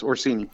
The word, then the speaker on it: Orsini: Is it Orsini? Is that am I pronouncing it right? – Yes, Orsini.